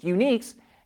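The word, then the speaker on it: ID. UNYX: Its first model is the IDUNYX, which we think is pronounced uniques, and we first showed you back in March.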